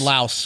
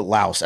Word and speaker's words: Laos: We got in trouble because listener Xavier's girlfriend let us know that the S in Laos is actually silent. So it's Lao.